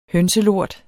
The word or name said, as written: hønselort